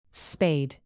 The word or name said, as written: spayed